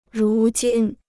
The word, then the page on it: 如今: rú jīn